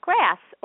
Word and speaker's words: GRAS: GRAS, which sounds like the word grass, stands for generally recognized as safe.